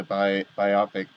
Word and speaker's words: biopic: Biopic. Biopic. Like pick is the word there for picture, and bio is biography.